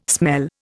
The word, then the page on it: smell: Indian